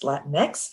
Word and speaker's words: Latinx: That's how it's pronounced, Latinx.